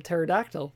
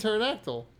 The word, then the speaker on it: pterodactyl: It's because you don't pronounce the P in pterodactyl.